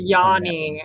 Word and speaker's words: Yanni: I keep saying his name wrong. Sorry. – It's Yanni. Yanni Gord.